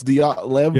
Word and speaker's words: Dyatlov: I am saying it right. It's Dyatlov yeah. or Dyatlov.